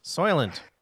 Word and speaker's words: Soylent: I'm drinking soil, the vanilla Soylent. Oh, I don't even know how you say it. – You just said it, sort of. Soylent.